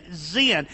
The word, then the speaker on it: Sin: Now, it's spelled S-I-N. It's said Z-E-N, Zin. That's how it's pronounced.